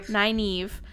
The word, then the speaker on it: Nynaeve: Nynaeve.